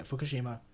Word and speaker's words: Fukushima: Yeah, Fukushima.